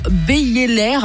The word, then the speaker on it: Beyeler: En fait, c'est la fondation Beyeler.